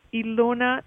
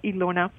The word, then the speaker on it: Ilona: So, yeah, if you go to my website, Ilona Selke, and I'm going to spell my name because most people in America probably wouldn't know how to spell Ilona.